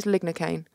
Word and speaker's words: lignocaine: Oh, did I get the name wrong? Oh, no, it is lignocaine. Because they've got their... – Lidocaine is something different. – No, it's also known as lignocaine.